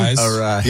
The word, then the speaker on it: Arraez: Arraez.